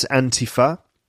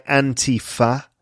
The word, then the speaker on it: Antifa: according to Bray, the guy who wrote the anti-fascist handbook, there are multiple different pronunciations. In the United States, most people say antifa. In Europe, it's sometimes referred to as antifa. So antifa, antifa, which Bray believes has been influenced by the Italian word antifascismo.